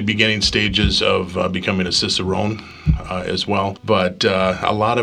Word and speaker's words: Cicerone: Cicerone.